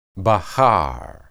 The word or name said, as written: bahar